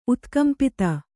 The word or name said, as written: ಉತ್ಕಂಪಿತ